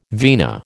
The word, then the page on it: vina: American English